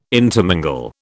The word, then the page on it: intermingle: British English